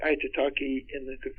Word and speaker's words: Aitutaki: It's the top of a volcano, and it sunk, the little tops of it sticking above the water, and it's Aitutaki. And it's spelled A-I-T-U-T-A-K-I. I have to think about it. Aitutaki.